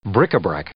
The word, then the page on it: bric-a-brac: noun